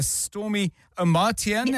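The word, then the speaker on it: Stormie Omartian: It's called The Power of a Praying Woman by Stormy Amartian. Have I said that right? – Yes. You said it right.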